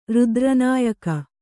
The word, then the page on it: ರುದ್ರನಾಯಕ: kannada